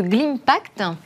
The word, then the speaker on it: Glimpact: Vous êtes président de Glimpact je le prononce correctement Très bien. Comme Global Impact.